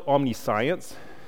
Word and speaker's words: omniscience: It's spelled omniscience. it's not said that. How is it said? Omniscience is the way you actually say it.